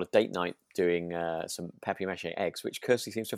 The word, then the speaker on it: papier-mâché: For which they need these papier-mâché? Am I saying that right? – I'd say papier-mâché.